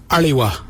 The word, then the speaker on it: aliwa: It's spelled A-L-I. Now let's add some suffixes with ali. So if we add W-A, it'd be aliwa.